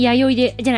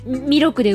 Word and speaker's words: 弥勒: 化粧しさらせ、右に左に弥生で、じゃない、弥勒で上にです。弥生って読んじゃうんだよ、どうしても。